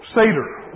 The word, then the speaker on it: Seder: What is the Seder meal? Am I saying it right? Okay. Huh? – Seder.